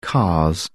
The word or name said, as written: cars